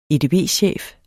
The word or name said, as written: edb-chef